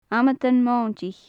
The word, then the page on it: amadan-mointich: gaelic